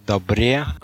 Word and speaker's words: Dabrye: D-A-B-R-Y-E. – Добре.